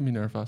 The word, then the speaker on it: Minerva: Ja, echt een, eh, dat zijn wel de, de, de grootste natuurlijk, hè, Minerva.